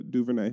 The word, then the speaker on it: DuVernay: Well, I think patient zero was Ava DuVernay.